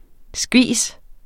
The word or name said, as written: skvis